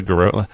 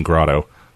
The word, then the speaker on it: garrote: A garrote. Yeah, that sounds way better than grotto.